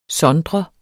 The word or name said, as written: sondre